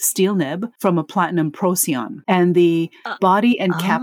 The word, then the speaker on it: Procyon: And I don't even know if I'm pronouncing that right. Procyon, I guess so. – I believe you are. Yeah. Procyon.